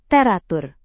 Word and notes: teratur: indonesian

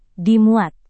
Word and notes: dimuat: Indonesian

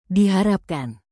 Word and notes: diharapkan: indonesian

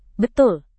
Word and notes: betul: Indonesian

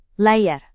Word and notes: layar: indonesian

